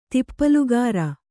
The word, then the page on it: ತಿಪ್ಪಲುಗಾರ: kannada